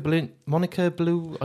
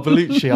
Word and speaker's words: Bellucci: Now, Monica Blue. I can't. I want to get my pronunciation. – Bellucci.